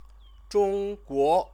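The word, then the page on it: 中国: Zhōngguó; Chinese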